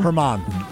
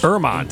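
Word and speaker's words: Germán: Domingo Germain. Is that how you say his name? – Herman. – German. – Herman. – Soft G. – She makes H. – Erman.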